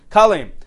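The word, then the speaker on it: Kalim (כלם): Kalim. Chaf Lamid Mem. Okay, it's very easy to remember. It sounds like the word kill him in English, right?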